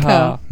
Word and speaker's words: Picard: Ähm, die Aussprache, also ich finde ja, also für mich heißt der Captain ganz deutlich Jean-Luc Picard. – Picard. Mhm. Ne, französisch ausgesprochen.